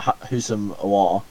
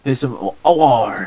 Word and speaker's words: Houssam Aouar: So apparently there's rumours about Houssam Aouar. Is that how you say it? Houssam Aouar.